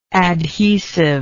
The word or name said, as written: adhesiveness